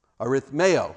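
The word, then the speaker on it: Arithmeo (ἀριθμέω): Arithmeo, actually the emphasis is on the A.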